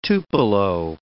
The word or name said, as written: tupelo